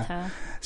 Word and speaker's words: Arteta: Arteta.